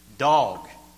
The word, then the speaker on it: Doeg: Well, I call him Dog. It's spelled Doeg, D-O-E-G. Dog, the Edomite.